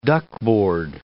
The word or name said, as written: duckboard